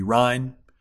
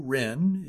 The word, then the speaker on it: Ryn: A local singer, Drew Ryn, I'm going to pronounce that R-Y-N. She spells her last name. I'm going to pronounce it Ryn. Could be Rhine.